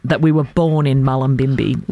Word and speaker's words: Mullumbimby: I think it's Mullumbimby is how it's pronounced. – Mullumbimby.